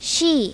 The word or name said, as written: she